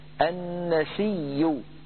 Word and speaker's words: النسيء: ورش رحمه الله روى هذه الكلمة عن شيخه نافع إنما النسيء زيادة في الكفر، النسيء بياء مشددة مضمومة. كيف حدث هذا؟ النسيء أبدل هذه الهمزة ياء مضمومة وقبلها ياء ساكنة، فأدغم الياء الساكنة في الياء المتحركة بعدها، فصار النطق بياء مشددة مضمومة كما لفظتها أمامكم.